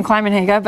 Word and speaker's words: Climenhaga: and telling them how to pronounce my name, Kleimenhage, but anyways, this city is huge.